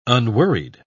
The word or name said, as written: unworried